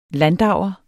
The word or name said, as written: landauer